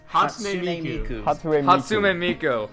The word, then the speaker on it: Hatsune Miku: Hatsune Miku.